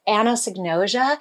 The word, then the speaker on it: anosognosia: But there's a pattern of thinking, and there's this thing, I had to look up how to say it because I guess I was saying it wrong for years, but it's anosognosia.